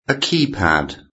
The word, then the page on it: keypad: British English